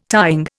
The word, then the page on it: tying: Indian